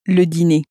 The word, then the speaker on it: dîner: Again, make sure to pronounce the dinner as le dîner. – And actually, dinner in French also ends in er, just as these other words do. So it has a similar pronunciation at the end as that cafe e sound. So the cafe e at the end of the word or the er at the end of a word both have that a sound in French.